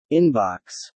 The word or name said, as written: inbox